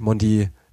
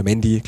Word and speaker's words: Mendy: Mondi oder Mendy, glaube ich, ist die richtige Aussprache bei ihm,